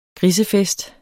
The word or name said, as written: GRISEFEST